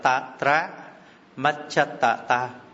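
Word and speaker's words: tatramajjhattatā: Tiếp đến tatra macchata ta, chữ này đọc là tatra macchata ta, là trung hòa tâm sở, là pháp làm cho tâm và tâm sở tiến hành nhiệm vụ một cách đồng đều.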